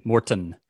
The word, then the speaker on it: Morton: Morton. – Morton, you got to pronounce the T, guys.